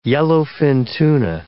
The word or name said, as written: yellowfin tuna